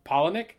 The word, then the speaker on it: Palahniuk: And then the other one is a book called Pygmy by, and I had to Google how to say this motherfucker's last name, Chuck Palahniuk. Because it looks wildly, like, that is not how I would have ever pronounced it without looking it up. That shit looks like Palahniuk, and I would have never came up with that.